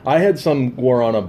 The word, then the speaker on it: Guarana: So what we got is, I don't know how you pronounce it, but it's spelled B-A-W-L-S. – Balls. – Balls. Guarna? – Guarana? Guarana? – G-U-A-R-A-N-A.